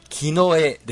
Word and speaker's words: 甲: キノエです。 – うんうん、亀の甲羅の甲と書いてね、これキノエって読みます。